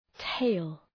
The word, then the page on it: tail: teıl